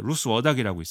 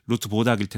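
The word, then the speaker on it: Ruth Wodak: 그리고 오스트리아 언어학자 루스 워닥이라고 있습니다. 이거는 네. 이제 영어식 발음이고 그 나라 발음으로 하면은 루트 보닥일 텐데 네.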